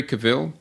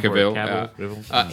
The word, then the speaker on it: Cavill: When you look at Superman, the last movie, Henry Cavill, that's how you pronounce his last name, or Cavill.